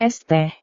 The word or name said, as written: es teh